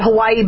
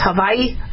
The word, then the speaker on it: Hawaii: A lot of people are very frustrated because, and we call it Hawaii, but it's Hawaii, right? The W is pronounced V.